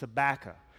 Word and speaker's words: Habakkuk: Here's how you pronounce it. It's Habakkuk. So all you got to do is pretend like you're saying tobacco. You take the T away, you replace it with an H, and boom, you've got it, Habakkuk.